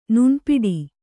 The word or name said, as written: ನುಣ್ಪಿಡು